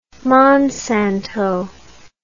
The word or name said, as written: monsanto